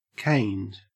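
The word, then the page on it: caned: au Australian English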